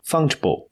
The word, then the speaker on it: fungible: fun fungible fungible token 就是发音还是要正确哈，不然会变成一个坏榜样，是不是？